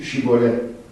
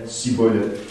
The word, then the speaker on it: Shibboleth: Il lui disait alors, eh bien, dis, Shibboleth. Et il disait, Sibboleth, car il ne pouvait pas bien prononcer.